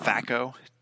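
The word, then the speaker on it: THACC0: Um, Thaco? – Yeah it's spelled T A.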